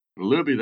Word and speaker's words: Luebbe: Luebbe, that's right.